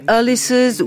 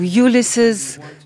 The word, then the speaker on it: Ulysses: Tales of Brave Ulysses ou Ulysses, selon les prononciations,